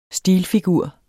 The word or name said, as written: stilfigur